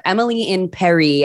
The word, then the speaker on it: Emily in Paris: We don't usually do successive seasons, but this is season two of Emily in Paris, as we were all told after the show premiered, that's apparently how you pronounce it.